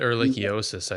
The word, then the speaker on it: ehrlichiosis: By the time they figured out what he had, and I have trouble pronouncing. – He had Ehrlichiosis, I think. – Yep, that's it.